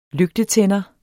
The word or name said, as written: lygtetænder